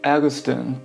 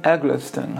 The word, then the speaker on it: Eggleston: 现在看到这本影集是 William Eggleston 导览，我以前经常会读错，把它读成 William Eggleston，好像也可以。